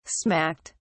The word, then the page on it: smacked: British English